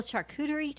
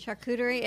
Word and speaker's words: charcuterie: Charcuterie. And it's so not on our point list, though. That's the other thing. – Charcuterie. – I don't understand. It's like Sharika. – A charcuterie tray.